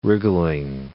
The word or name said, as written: wriggling